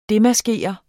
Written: DEMASKER

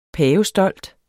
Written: pavestolt

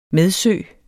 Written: medsø

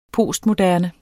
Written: postmoderne

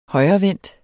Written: højrevendt

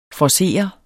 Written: forcere

